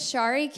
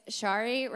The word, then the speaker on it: Sharie: So, um, my name is Sharie King. Uh, it rhymes with sorry. If you forget it, that's okay because everyone does. And so it's Sharie rhymes with sorry and last name King, which is easier K I N G.